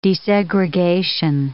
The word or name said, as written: desegregation